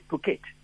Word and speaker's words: Phuket: Now, PH in English is an F, but we still pronounce it Phuket.